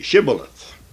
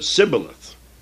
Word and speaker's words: Shibboleth: If he said, nay, then they said unto him, say, Shibboleth. And they would pronounce it Sibboleth. For these people, could not frame this word right.